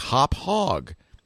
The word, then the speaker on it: Hauppauge: They says, well, it's pronounced like hop hog.